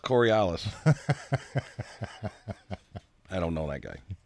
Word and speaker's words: Coriolis: Corlanilis. There's no N in there. – There it is. – Still can't even say it right. Coriolis.